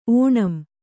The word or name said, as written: ಓಣಂ